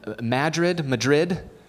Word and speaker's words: Madrid: I looked up on the census in Madrid. Yeah, apparently that's how you say it.